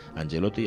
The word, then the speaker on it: Angelotti: Lo ponga sobre la pista de Mario, porque él sospecha, y con razón, que Mario está ayudando a Ancelotti. No Ancelotti. Ancelotti era el del Madrid. Es Ancelotti. Que era un huido. Es que ayer pensé que era, pero no, se pronuncia de forma distinta. Angelotti y Ancelotti.